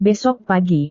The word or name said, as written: besok pagi